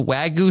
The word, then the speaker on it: Wagyu: it's called the Wagyu, W-A-G-Y-U.